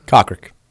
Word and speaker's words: Kokrak: It's real. K-O-K-R-A-K. Cockrick. Not a bad golfer.